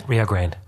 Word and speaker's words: Rio Grande: How do the Americans pronounce it? – Rio Grande. It's after the river.